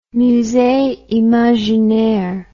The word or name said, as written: musee imaginaire